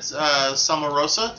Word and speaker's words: Somarosa: I like that Somarosa, did I say it right? – Yep.